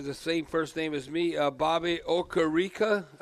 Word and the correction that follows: Okereke: uh, Riki, or how do you say his uh, name? Bobby Okereke. – Oh, Okereke.